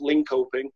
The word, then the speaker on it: Linköping: If you look on the map, there's a town called, or a city called Linköping, or you'd maybe pronounce it Linköping.